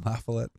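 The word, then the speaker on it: La Follette: Is it in the basement next to Doug LaFollette, or however you say his name? – LaFollette.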